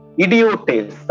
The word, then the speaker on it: idiotes: Okay, the original word that's used there is the word idiotes. That's how it's pronounced. Okay. As an ignoramus, okay, or the spelling goes I-D-I-O-T-E-S.